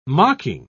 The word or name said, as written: mocking